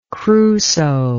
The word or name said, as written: crusoe